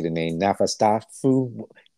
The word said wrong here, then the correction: Nosferatu: You know that Nosferatu. – Nosferatu.